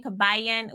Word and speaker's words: Kabayan: And I don't know, they're saying Kabayan.